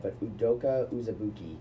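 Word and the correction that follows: Azubuike: It's Azubuke. – Azubuke.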